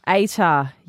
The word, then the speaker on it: ATAR: ATAR, is it? How do you say it? – ATAR. – Yeah.